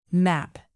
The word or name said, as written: map